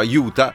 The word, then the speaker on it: Utah: In spagnolo si pronunciava Utah e le persone in lingua inglese lo hanno incominciato a pronunciare Utah con la U davanti al posto della Y e la H finale.